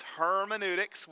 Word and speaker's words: hermeneutics: It's hermeneutics.